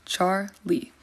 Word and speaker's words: Charley: It is pronounced exactly the way it is spelled, Charley.